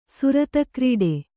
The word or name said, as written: ಸುರತಕ್ರೀಡೆ